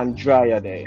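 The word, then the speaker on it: Andra Day: We have from Lady Gaga to J-Lo, I think to Andrea Day. I'm not sure if I'm saying it correctly. Andrea Day. She sang that song that called Rise Up. Yeah. – Yes, you're saying it right.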